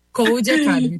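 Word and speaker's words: Codecademy: Então, seja bem-vinda, eu vou te pedir para se apresentar e começar falando para a gente o que, que é a Curi Academy. Curi Academy. Falei certo, né? – Não. Code. – Code Academy, perfeito. – Code Academy, isso.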